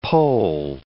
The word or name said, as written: pole